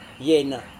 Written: yena

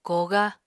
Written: koogá

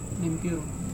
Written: nempiurung

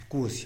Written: pkuz

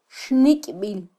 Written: xnikbil

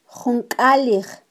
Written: junkalij

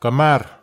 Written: kamar

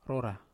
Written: rora

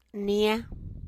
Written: nie

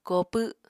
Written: koopw

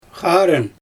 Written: xarɯn

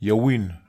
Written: iawin

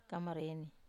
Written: kamareni

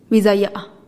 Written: bizaya'a

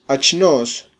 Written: achnos

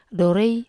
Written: do re

